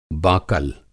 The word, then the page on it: बाकल: Hindi